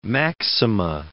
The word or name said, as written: maxima